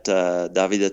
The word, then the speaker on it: Davide: Yeah, the best way is to follow me on Instagram at Davide Travelli. Travelli is my real last name. It's spelled like David with one E at the end, Travelli.